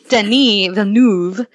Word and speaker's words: Denis Villeneuve: And the director, and I'm going to say his name one more time because I know how to, Denis Villeneuve, Arrival was one of my favorite films from last year, like just the top.